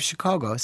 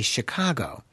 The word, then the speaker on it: Chicago: Now, people outside of Chicago say Chicago. That is not the way we in Chicago pronounce it. We pronounce it Chicago, which is more of an aw sound versus an ah sound.